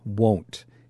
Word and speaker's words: won't: Write that word the way that you would pronounce it. So just like the way you pronounce, oh my God, that exclamation, oh, spell it like that when to remember the pronunciation. Whoa, won't, okay?